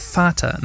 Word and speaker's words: Vater: Yeah, Vater, not Vater, because V in German is pronounced like F.